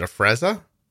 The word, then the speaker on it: Afrezza: Is it Afrezza? – You said it perfectly.